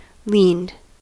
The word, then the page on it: leaned: American English